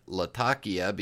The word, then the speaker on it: Latakia: Latakia, or as it's properly pronounced, Latakia, because it's named after the port town in Syria, Latakia is a process that is fire curing a specific type of Oriental tobacco.